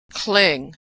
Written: cling

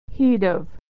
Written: he'd have

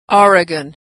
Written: Oregon

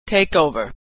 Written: takeover

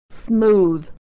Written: smooth

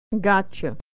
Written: gotcha

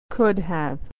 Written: could have

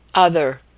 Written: other

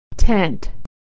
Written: tent

